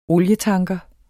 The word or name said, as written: olietanker